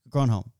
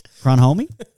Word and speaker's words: Grandhomme: Now I've heard it, I'm like, why did I get Colin de Grandhomme? – De Grandhomme. – Grandhomme. – Yeah. Well done. Well done. And what was the pronunciation last week? – Grandhomie? – Call it to Grandhomie.